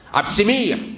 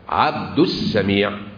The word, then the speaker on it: عبد السميع: عبد سميع، ها؟ عبد سميع، ايه دي؟ عبد السميع.